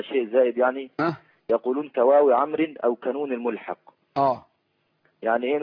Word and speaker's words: عمرو: لكن واو عمرو واضحة في أن الواو تكتب ولا تنطق،